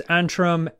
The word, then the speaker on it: Antrum: This is Antrim, A-N-T-R-U-M. – Okay. – Not I-M.